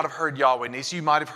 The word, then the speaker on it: Jehovah: Jehovah is an alternate way to say or to pronounce Yahweh. Ancient Hebrew had no vowels. It was all consonants. Yahweh was spelled YHWH. But in some languages, Y has a J sound and W has a V sound.